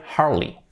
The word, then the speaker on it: Harley: También la marca de motos, Harley, no se dice Harley, se dice Harley. Harley. Harley Davidson.